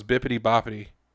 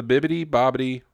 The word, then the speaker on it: Bibbidi Bobbidi: Dang, I thought it was Bippity Boppity. Whew, I've been saying it wrong for quite some time, and that's embarrassing. But it's the Bibbidi Bobbidi Boutique.